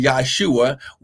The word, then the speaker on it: Yahshua: We've been taught, and as you go through the word of the gospel, we understand that Jesus, or actually Yahshua, was his actual pronunciation because there's no J in the Hebrew language.